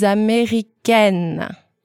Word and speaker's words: américaine: I would say, je suis américaine. – Repeat the male version one more time. – Américain, this is male. Américaine, this is female.